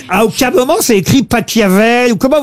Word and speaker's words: Pachelbel: Catelloni, c'est Pachelbel. – S'il est allemand, ça se dit Pachelbel.